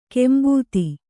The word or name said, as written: ಕಾಂಬೋದಿ